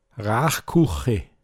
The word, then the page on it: raischpen: Pinzgauer Mundart